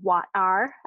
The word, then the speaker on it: Wat-R: So at the same time that this is happening, everyone's drinking this thing called Wat-R. I pronounce it that way because I feel like it should sound like there's something kind of caught in your throat.